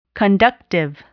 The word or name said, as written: conductive